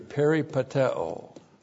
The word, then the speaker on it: peripateo: The verb here is peripateo. Looks like this in the Greek. P-E-R-I-P-A-T-E long O. Peripateo.